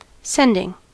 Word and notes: sending: American English

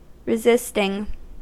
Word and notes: resisting: American English

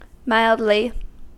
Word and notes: mildly: American English

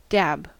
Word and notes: dab: American English

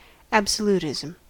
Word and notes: absolutism: American English

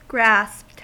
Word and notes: grasped: American English

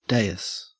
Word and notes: dais: Australian English

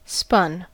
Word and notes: spun: American English

